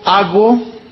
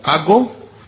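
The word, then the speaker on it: ago (ἄγω): viene del griego ago, con acento en la ago, y significa conducidos, significa inducidos, y significa también llevados.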